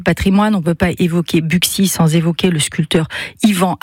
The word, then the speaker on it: Buxy: Buxy serait le seul nom de commune situé dans l'ancienne province bourguignonne comportant un X au milieu, hein, où ce X se prononce X.